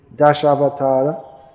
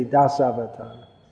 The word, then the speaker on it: Dashavatara: He's the third in the Dashavatara. How do you say that in Kannada? Dasavatara? In Hindi they say Dasavatara. Das. In Sanskrit it should be Dash. Sha. In Kannada also? Sha. Yeah. Hindi, they're lazy.